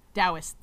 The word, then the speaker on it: Taoist: Taoist. The T is pronounced with a D.